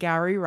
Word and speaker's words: Gowrie: Yeah, I profiled Our and Gowrie Rice. – Our and Gowrie. – And we learned the trick in her Instagram bio of how to pronounce her name. It rhymes with flower in dowry.